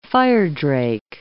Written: firedrake

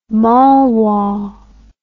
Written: malwa

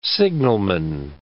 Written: signalman

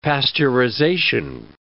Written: pasteurization

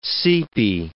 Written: seepy

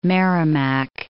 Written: Merrimack

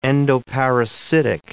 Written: endoparasitic

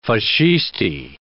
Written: Fascisti

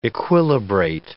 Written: equilibrate

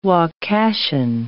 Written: Wakashan

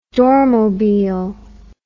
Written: dormobile

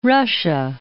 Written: Russia

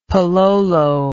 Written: palolo

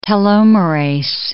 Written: telomerase

